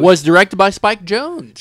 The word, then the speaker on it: Jonze: Talk no, it's pronounced as Jonze.